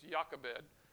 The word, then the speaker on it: Jochebed: It's spelled with a J, but they pronounce it like a Y, so it's Jochebed.